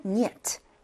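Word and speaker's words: 니엣 (нет): 그래서 이걸 읽으면 이게 니은 발음이고 이게 예니까 니엣, 니엣, no, 아니요라는 의미를 가진 표현이에요.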